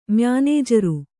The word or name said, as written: ಮ್ಯಾನೇಜರು